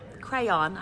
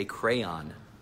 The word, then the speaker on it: crayon: This is a crayon. Crayon. Two syllables. – Okay, this is an orange crayon. Crayon is how you say it.